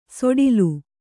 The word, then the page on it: ಸೊಡಿಲು: soḍilu